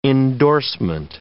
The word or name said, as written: endorsement